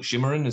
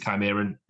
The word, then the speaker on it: Chimeran: I would hard see it Chimeran. I'm sure some people call it Shimmerin as well. I'm going to stick to Chimeran.